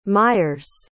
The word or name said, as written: mires